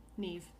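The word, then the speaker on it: Niamh: N-I-E-V-E-S? – No, so it's N-I-A-M-H, but in Gaelic, M-H is a V sound. – Mm-hmm. Yeah, so Neve. Okay, got it. – N-A-I-M-H pronunciation.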